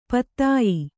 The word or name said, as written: ಪತ್ಯ